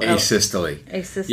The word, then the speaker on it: asystole: how do you say this word A-S-Y-S-T-O-L asystole yeah so basically a flatline typically the algorithm for treating that is not shocking.